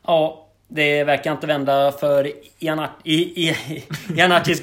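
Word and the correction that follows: Iachini: gubbar där. Iakini. Iakini, förlåt. Det uttalet är inte på sin rätt idag.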